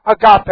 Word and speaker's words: agape: Point one, love is the Greek word agape, A-G-A-P, long E. Patient. The Greek word is macrothumia, for patient. Macrothumia, M-A-K-R-O-T-H-U-M-I-A.